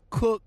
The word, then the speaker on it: Koch: 40 years strong, and it's pronounced Cook, but the spelling is K-O-C-H.